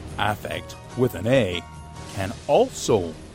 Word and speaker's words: affect: And in that field, it's pronounced affect with the emphasis clearly on the first syllable.